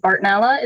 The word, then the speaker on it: Bartonella: the Bartonella. Is that the right way to say that? – Yes.